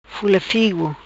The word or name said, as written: Fula Figo